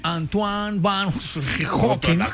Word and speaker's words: Antoine van Leeuwenhoek: Antoine van Leeuwenhoek. Es que así se pronuncia. ¿Usted por qué se burla de mí? Estoy pronunciando en holandés, Pipe.